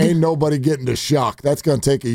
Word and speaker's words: Shough: Spelled S-H-O-U-G-H. Pronounced Shuck.